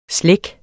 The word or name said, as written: slække